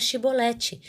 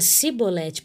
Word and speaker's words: Chibolete: E se ele dissesse não, então lhe diziam: diz agora Chibolete. E ele dizia Cibolete, pois ele não conseguia pronunciar corretamente.